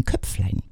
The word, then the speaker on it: Köpflein: Mein Köpflein, Betonung auf Lein.